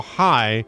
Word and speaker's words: Hey: It's Kyle High. I just stop on names like that, that you pronounce it high and it's spelled H-E-Y.